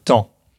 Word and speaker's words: taon: Ça s'écrit T-A-O-N, mais ça se prononce comme euh, temps.